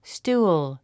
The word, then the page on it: stool: stúːl; ストゥーォ